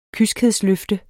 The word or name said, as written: kyskhedsløfte